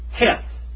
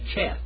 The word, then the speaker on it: Cheth: Now in the German pronunciation of this is it is Heth. Say Heth. Okay, now the Hebrews say Cheth. Cheth. C H. Cheth.